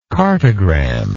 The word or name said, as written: cartogram